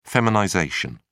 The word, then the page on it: feminization: British English